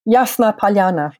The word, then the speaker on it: Yasnaya Polyana: How do I pronounce it? Yasnaya Polyana? – Yasna Polyana.